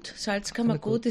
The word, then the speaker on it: Salzkammergut: Bueno, Salzkammergut es la región que en, digamos, engloba parte de la región de los lagos de la sal.